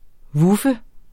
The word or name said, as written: vuffe